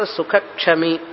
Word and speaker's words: సుఖః క్షమీ: 2వ లైన్లో క్షకారానికి ముందు విసర్గ వస్తే అది పూర్తిగా విసర్గ ఉచ్చారణ చేయాలి. సమదుఃఖ సుఖః అని చెప్పి క్షమీ అని చెప్పాలి. సమదుఃఖ సుఖ క్షమీ, సుఖక్షమీ అనకూడదు, సుఖః క్షమీ అనాలి. ఎప్పుడెప్పుడైతే క్షకారం వస్తుందో విసర్గ తర్వాత, ఆ విసర్గని హకారంగా చదవాలి.